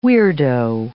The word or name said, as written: weirdo